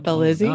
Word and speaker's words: Belizzi: It's Karina Bell, like to ring a bell. Izzy? – Okay. Yeah. – Belizzi.